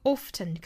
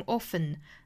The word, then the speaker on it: often: 这个 often 可以读成 often, 那 often 都是可以的，也就是这个 t 的音是可以发也可以不发的。